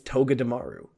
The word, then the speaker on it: Togedemaru: Togedemaru. So many pronunciation problems. It's Togedemaru. That's how you say it.